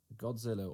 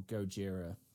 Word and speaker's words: Gojira: So for your chosen topic, as the audience would be aware from the intro, you've gone for the king of monsters himself, Godzilla or Gojira, depending on your pronunciation.